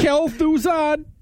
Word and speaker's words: Kel'Thuzad: Kel'Thuzad! – Yeah, good, that's about right.